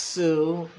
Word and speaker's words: Hsu: Her name, I assume, is pronounced Sue. And the H is silent, I believe.